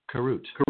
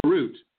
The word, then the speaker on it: Karut: And tell us a little bit, am I pronouncing it Karut? – Karut. – Karut. Okay.